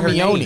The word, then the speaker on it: Hermione: Hermione. I didn't even know how to Perfect. Say I didn't even know how to say Hermione.